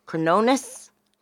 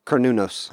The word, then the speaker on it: Cronus: Cronus. – Cronus.